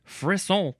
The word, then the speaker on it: frisson: Moving on to frisson. F-R-I-S-S-O-N. Pronounced frisson.